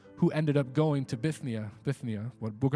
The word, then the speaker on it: Bithynia: If you see this idea of Bithynia, sorry, I can't pronounce that right. Bithynia. Am I saying that right? Bithynia? Yeah. Was it? Bithynia, praise God.